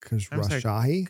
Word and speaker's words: Khosrowshahi: It's Khosrowshahi. Khosrowshahi. – Could you go faster? – Sure. Khosrowshahi.